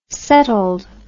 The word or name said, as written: settled